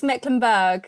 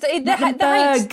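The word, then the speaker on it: Mecklenburgh: It's Mecklenburg. So just Mecklenburg. The H isn't there. Okay. Silent H. Mecklenburg.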